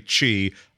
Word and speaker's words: Qi: It's not I haven't programmed yet to simply say Qi. I've programmed myself to say, oh, that's right. It's this Qi, right? The thing that if you grew up in a Western culture, you would not imagine is pronounced that way.